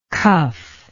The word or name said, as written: cuffed